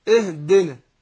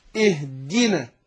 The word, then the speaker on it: اهدنا: ما اقولش اهدنا دنا اهدنا، يبقى انا ما بينتش الكسر اللي فيه، لكن اقول ايه اهدنا اهدنا الصراط، اهدنا الصي، اهدنا الصي. يبقى هنا بينا الايه الكسر اللي في الدال، اهدي اهدنا.